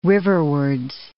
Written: riverwards

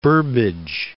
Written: Burbage

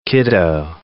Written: kiddo

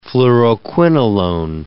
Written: fluoroquinolone

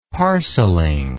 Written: parceling

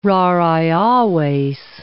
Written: rarae aves

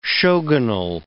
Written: shogunal

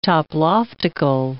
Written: toploftical

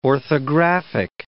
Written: orthographic

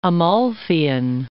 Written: Amalfian

